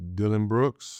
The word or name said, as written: Dillon Brooks